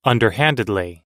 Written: underhandedly

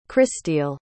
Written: christille

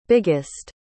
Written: biggest

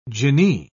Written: jinni

